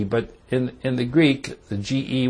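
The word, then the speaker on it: ge (γῆ): Now, the word earth is the word ge, G-E. It's pronounced ge in the Greek language.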